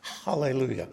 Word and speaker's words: Hallelujah: That's called a rough breathing, and it means when you pronounce that word, you start with a H. Hallelujah.